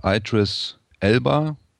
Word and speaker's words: Idris Elba: Idris Elba, sagst du so richtig? – Ja, richtig.